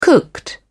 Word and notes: cooked: British English

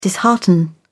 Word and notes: dishearten: British English

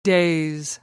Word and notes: daze: American English

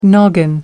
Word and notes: noggin: American English

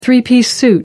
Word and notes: three piece suit: American English

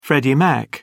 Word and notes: Freddie Mac: British English

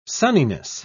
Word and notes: sunniness: British English

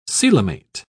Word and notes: coelomate: British English

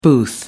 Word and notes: booth: American English

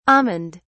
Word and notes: almond: British English

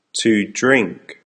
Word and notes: to drink: American English

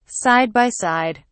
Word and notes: side by side: British English

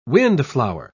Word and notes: windflower: British English

